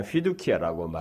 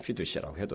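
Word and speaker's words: fiducia: 이것을 흔히 휘두키아라고 말하는데, 뭐 라틴어는 C를 K로도 발음하고 S로도 발음하기 때문에 휘두시아라고 해도 상관은 없어요.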